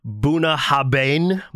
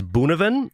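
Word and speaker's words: Bunnahabhain: It is spelled it's spelled like Buna Habane, but I believe it is Boonevin.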